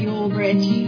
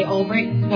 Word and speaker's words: Ulbricht: Looks like it should be pronounced Ulbricht. But we say Ulbricht, so there's no K in sight.